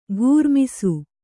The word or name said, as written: ಘೂರ್ಮಿಸು